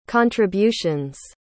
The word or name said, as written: contributions